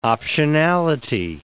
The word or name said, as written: optionality